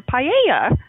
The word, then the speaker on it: paella: but let's just quickly chat about paella. I don't know if I'm saying that right. Paella? How do you say that correctly? – Paella.